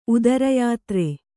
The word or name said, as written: ಉದರಯಾತ್ರೆ